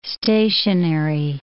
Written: stationery

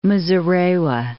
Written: Muzorewa

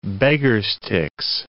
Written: beggar's-ticks

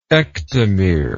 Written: ectomeric

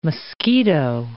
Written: mosquito

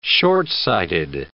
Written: shortsighted

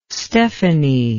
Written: stephanie

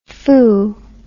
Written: phoo

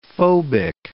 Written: phobic

